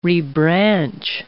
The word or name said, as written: rebranch